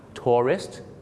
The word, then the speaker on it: tourist: ในการออกเสียงคือพยางค์สองพยางค์ Tourist นักท่องเที่ยวกับ Terrorist สามพยางค์ครับผมนะกลัวนะครับว่าคุณจะเป็นเหมือนลูกศิษย์ผมคนนึงครับฝรั่งถามว่า Why did you come to America?